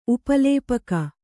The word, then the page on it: ಉಪಲೇಪಕ: upa lēpaka